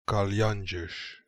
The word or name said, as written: Kalianjesh